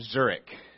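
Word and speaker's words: Zürich: 40 miles from Zurich.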